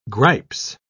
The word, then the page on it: gripes: British English